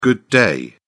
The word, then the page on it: good day: British English